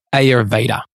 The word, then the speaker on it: Ayurveda: And again, keep coming back to Ayurveda because, and by the way, we get pulled up on how we pronounce Ayurveda. We know how to pronounce it. It's actually Ayurved. – Yeah, because they don't say, they don't say ours. – We know how to say it, but it feels like. – Whatever. Like, yeah. However you want to say it, just say it. – If you're in Australia, you'd say Ayurveda.